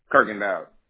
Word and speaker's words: Kirkendoll: Kirkendall. – Can you spell that? – K-I-R-K-E-N-D-O-L-L. – So it's K-I-R-K-E-N-D-O-L-L? – Yeah.